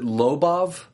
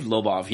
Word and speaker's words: Lobov: Our boy, my boy, Artem, is it Lobov? – I believe Lobov, yeah, that's how I'd pronounce it.